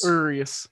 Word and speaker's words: Urias: But your point about Urias is, and I never say his name right, Urias. – Urias, yeah. – Like curious without the C.